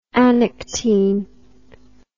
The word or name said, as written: Anectine